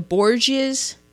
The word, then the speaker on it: Borgias: The Borgias? Did I say that correctly? – Yeah.